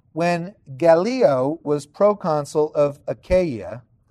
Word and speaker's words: Achaia: Okay, now Achaia, like I said earlier, but that's the correct pronunciation now, is the Roman province in southern Greece.